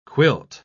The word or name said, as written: quilt